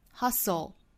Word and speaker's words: hustle: 的最后一个位置 now 重读 If you stop comparing yourself to others now 还用了一个声调哈，后面半句 And if you hustle to pursue 这个 hustle 辅音连缀我们在发的时候是 soul hustle 注意舌侧音的舌头的摆位舌尖距底上齿音当然这里面的这个舌侧音呢还要跟前面的 s 去组合 hustle 我们注意这个单词里是含有一个字母 t 的但是字母 t 是不发音的哈我们在发的时候是 s 和 o 连在一起发 hustle